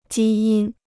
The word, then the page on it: 基因: jī yīn